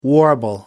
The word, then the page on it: warble: American English